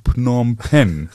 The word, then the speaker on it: Phnom Penh: Ich fürchte aber, wenn wir zu viel über das Buch jetzt sprechen, detailliert, also über die Python in Phnom Penh zum Beispiel.